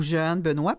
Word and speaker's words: Jehane: Jeanne Benoît ou Jeanne Benoît parce que le prénom s'écrit J-E-H-A-N-E.